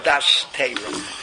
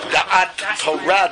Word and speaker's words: Da'at Torah: Das Torah. Pronounce it properly. Da'at Torah, they'll kick me out.